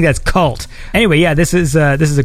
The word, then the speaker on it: Kvlt: I believe the album is called Cult, although it's spelled K-V-L-T. Is that that cool thing where you make a V instead of a U?